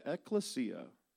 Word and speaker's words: ecclesia: For those of you on video, he said ecclesia. He's correct. I pronounce it because I'm hillbilly. Ecclesia.